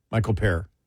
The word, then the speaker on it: Michael Paré: Michael Paré? – Michael Paré. – I think it's Michael Paré that is, that's what I remember his name is. – Yeah. – How they pronounced it.